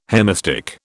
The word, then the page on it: hemistich: British English